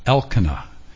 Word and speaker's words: Elkanah: And Elkanah, the name should be pronounced Elkanah, because El is the name of God in his name. And we want to emphasize El.